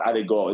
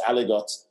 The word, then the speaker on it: aligot: So it's it's not it's not bras, it's not aligot, it's aligot and